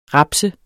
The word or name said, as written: rapse